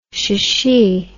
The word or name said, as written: shchi